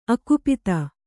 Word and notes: ಅಕುಪಿತೆ: kannada

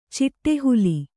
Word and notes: ಚಿಟ್ಟಹುಲ್ಲು: Kannada

